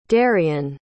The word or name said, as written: dereon